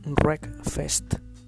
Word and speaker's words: Wreckfest: Se escribe, tiene una W al principio, pero se lee Wreckfest.